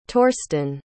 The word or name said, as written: torsten